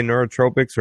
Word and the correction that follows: nootropics: or is it nootropics? – Nootropics, man.